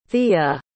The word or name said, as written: thea